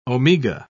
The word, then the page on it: omega: British English